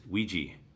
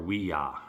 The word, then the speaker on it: Ouija: The number one movie a couple of weeks back was Ouija, or Ouija, however we want to pronounce it. – Ouija.